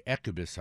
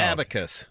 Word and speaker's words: abacus: It's if I get my ecubus out. Is that how you pronounce that? Ecubus, and figure it out. – Abacus.